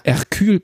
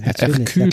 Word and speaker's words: Hercule: Und ähm, so in die Richtung, äh, ja, Hercule Poirot, genau, mit H, Ach, also Herkules. Hercule ja, Poirot.